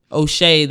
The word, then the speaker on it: O'Shea: So another O'Shea that rhymes with Jay.